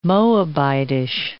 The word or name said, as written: Moabitish